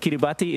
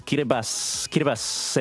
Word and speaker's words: Kiribati: We'll remind people every year on the during this week that it's not, like, it's spelled Kiribati, but it's not pronounced that way. Like, look, the spelling is one thing, but the T-I in the language, across the language, is pronounced as an S sound. So if you see a T-I, it's just an S. So not Kiribati, it's Kiribati. And anyways, any reminder out of the way.